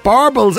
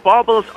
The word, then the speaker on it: baubles: Is it barbles or baubles? – Baubles or baubles. Sinead, what is this? Baubles? – Baubles. – Baubles, yeah, she just said baubles.